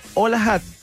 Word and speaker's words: HOLAHUT: Entra a pizzahat.cl, ingresa el código HOLAHAT, que se escribe HOLAHUT con H y llévate una auténtica pizza americana familiar.